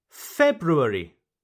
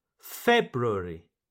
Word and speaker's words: February: February. February. Okay? Right. Now, pronunciation number two February. February. Here, you do not pronounce the first R.